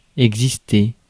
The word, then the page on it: exciter: /ɛk.si.te/